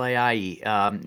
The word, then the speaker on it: Ayayi: I guess his name would be pronounced Joel Ayayi, since he's originally from Paris, France. What do you think of him? – Yes, Joel Ayayi.